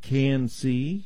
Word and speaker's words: CAN-C: It's called CAN-C, and it's spelled C-A-N for can. And then the capital letter C. So, CAN-C.